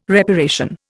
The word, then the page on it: reparation: Indian